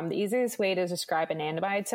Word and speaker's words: anandamide: There's one in particular compound that I'd like our listeners to understand, and that's anandamide. Am I saying that correctly? – Yes, you are. The bliss molecule. – The bliss molecule. That's why it's so important. So what's going on with anandamide? – Okay.